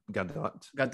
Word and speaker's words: Gadot: I think it's Gadot. – Gadot, yeah. Wonder Woman, aka Wonder Woman.